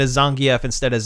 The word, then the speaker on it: Zangief: Yeah, did you know that Zangief is actually pronounced Zangief? – Really? – Yeah. – I didn't know that. – Apparently, it is actually pronounced Zangief, and they pronounce it that way in Street Fighter V.